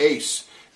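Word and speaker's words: eis (εἰς): Now, those words all come from a Greek word, eis. E-I-S is the way we would spell it in English.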